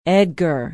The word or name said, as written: edgar